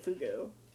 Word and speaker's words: fugu: aka the fugu.